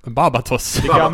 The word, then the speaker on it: Barbatos: Barbatos. – Det gamla slager, ja, precis. – Bandet. – Barbatos. – jag har uttalat det fel så många gånger, så när du uttalade det liksom, ja, rätt.